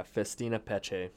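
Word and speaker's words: Festina Pêche: The Festina Peche. – Yeah, Festina Peche.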